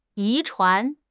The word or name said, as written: 遗传